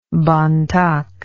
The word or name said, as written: Bontok